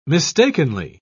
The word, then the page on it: mistakenly: British English